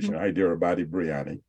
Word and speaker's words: Hyderabadi biryani: Hyderabadi and biryani, right? That's the pronunciation, Hyderabadi biryani.